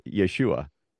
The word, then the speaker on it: Yeshua: So it's the second in the list here, Zerubbabel and Yeshua, which of course is the Aramaic pronunciation of the name of Jesus.